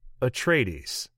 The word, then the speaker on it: Atreides: and I'm going to say this wrong, Atreides. Was that right? – That's totally right.